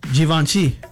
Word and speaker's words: Givenchy: Givenchy.